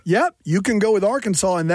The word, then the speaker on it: Arkansas: Arkansas is the way you have to pronounce it.